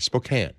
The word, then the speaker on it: Spokane: I should say Spokane. It's Spokane, Spokane. Spokane, Spokane. I can never say that right. Spokane. There you go.